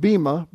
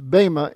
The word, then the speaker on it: Bema: Some people pronounce it Bema, but it's Bema in Greek.